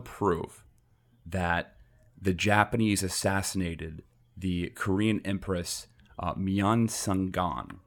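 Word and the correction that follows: Myeongseong: I think I'm pronouncing that right. Do you see that? – Myeong-sung. Yeah. The Korean Empress Myeong-sung.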